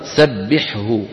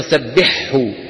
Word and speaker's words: سبحه: حاء وهاء، وهما حرفان حلقيان، الأول ساكن لا تدغم الحاء في الهاء، والذي نسمعه من العامة قولهم العكس، يدغمون الثاني في الأول فيقولون سبحه. ولما هذا؟